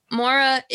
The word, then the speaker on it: Maura: So Mara, I'm not sure how her name is spelled. M-A-U-R-A. – Maura. – Okay. – Yeah, I always say Maura in my Maura.